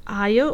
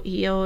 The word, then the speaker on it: Io: A heifer also appears in the myth of Io, or Io in original Greek pronunciation, one of the many objects of Zeus' lust.